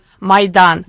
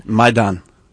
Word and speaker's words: Maidan: How do you pronounce it? – Maidan. – Maidan.